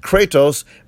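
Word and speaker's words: kratos (κράτος): The word strength there comes from a Greek word that is pronounced kratos, meaning dominion.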